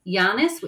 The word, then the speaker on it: Llanes: There's a couple of little famous places that on the sort of tourist radar is Llanes, which is spelt with a double L, A-N-E-S.